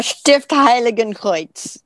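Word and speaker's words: Stift Heiligenkreuz: Yeah, Stift Heiligen Kreuz.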